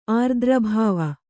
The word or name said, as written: ಆರ್ದ್ರಭಾವ